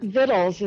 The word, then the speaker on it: victuals: So the word vittles is spelled V-I-C-T-U-A-L-S, and it is correctly pronounced and always has been correctly pronounced vittles.